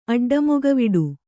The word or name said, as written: ಅಡ್ಡಮೊಗವಿಡು